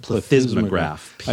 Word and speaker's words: plethysmograph: Plethysmograph.